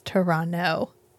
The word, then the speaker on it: Toronto: It's Toronto. You don't say the second T. – Sure. – It's fine. It's like Mackinac. – Yeah. – The last N-A-C is silent.